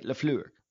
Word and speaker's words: LaFleur: You keep saying it wrong. Say it with me. Say it with me, right? LaFleur. – You gotta give that LaFleur. Flair, huh? – You gotta put the little French flair on it.